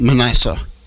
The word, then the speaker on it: Manasseh: 2 Kings 21:6, where King how do you say that one, Manasseh. – Manasseh also sacrificed his son, practicing witchcraft, and led Judah into deep idolatry.